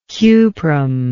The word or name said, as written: cuprum